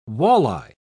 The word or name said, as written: walleye